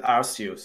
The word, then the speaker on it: Arceus: I would pronounce it Arceus, so...